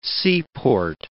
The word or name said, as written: seaport